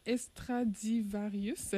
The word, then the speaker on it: Extradivarius: Et en plus de votre album « Femme », tu prévois sortir l'œuvre collective « Extraordinaire, Extradivarius » cet automne. – Oui, bravo. – je l'ai bien prononcé.